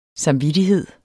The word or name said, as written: samvittighed